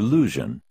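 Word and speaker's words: Luzhin: Grandmaster Lusion. The name rhymes with illusion if pronounced thickly enough to deepen the U into oo.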